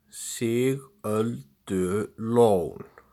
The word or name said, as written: Sigöldulón